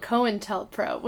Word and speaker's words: COINTELPRO: COINTELPRO.